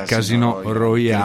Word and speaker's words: Casino Royale: è dei Casino Royale, perché lo pronuncio all'italiana, quindi Casino Royale.